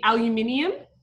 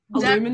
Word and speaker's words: aluminium: So we say aluminium. – Aluminum.